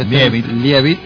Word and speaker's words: Leavitt: Leavitt.